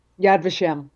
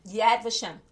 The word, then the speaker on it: Yad Vashem: You know, you mentioned in your bio, Yad Vashem, and I hope that I'm pronouncing that the right way. – Yad Vashem. – Yad Vashem.